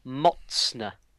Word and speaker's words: Mocne: it's pronounced Mocne, but it's spelt M-O-C-N-E.